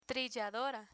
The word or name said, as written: trilladora